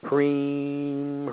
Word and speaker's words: Hrim: The mantra Hrim, H-R-I-M, but the I spends like long E, so it's like Hrim, Hrim.